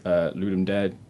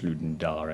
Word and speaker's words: Ludum Dare: But he's also a game developer, and he hasn't kind of released anything big yet, but he's always participates in, like, the Ludum Dare jams. I think that's actually pronounced Ludum Dare or something.